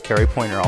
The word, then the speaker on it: Kerry Poynter: My name is spelled K-E-R-R-Y-P-O-Y-N-T-E-R. That's Carry Pointer, all one word.